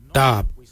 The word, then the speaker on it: dhaab: Today we will look at, for knife we say darb. Darb is spelled D-H-A-A-B. It is a traditional knife, a darb.